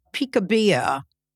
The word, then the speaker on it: Picabia: The author is the great-granddaughter of Francis Picabia.